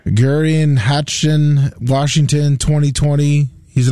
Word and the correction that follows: Geirean: What was that name you just butchered? – Geirean, G-E-I-R-E-A-N.